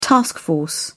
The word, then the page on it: task force: British English